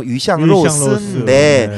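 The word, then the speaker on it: 위샹로스인데 (鱼香肉丝): 중어 발음 위샹로스인데 네.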